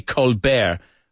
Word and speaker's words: Colbert: The T is silent. It's not Stephen Colbert. I used to think it was Stephen Colbert. And in fact, his brother goes by that name. Not Stephen, but the last name Colbert. But he pronounces it the French way, Colbert, Colbert.